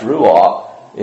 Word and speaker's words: ruach: And ruach means wind or breath or breathing, and written a little bit different, but still pronounced ruach, is the word smell in Hebrew.